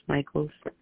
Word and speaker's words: Mickeals: My last name is MICKEALS. It's pronounced Michaels.